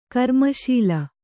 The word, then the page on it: ಕರ್ಮಶಾಲೆ: karmaśale